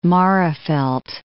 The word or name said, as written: Magherafelt